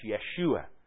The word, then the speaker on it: Yeshua: So his name was actually pronounced Yeshua.